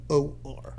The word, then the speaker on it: Namor: Because it's Namor.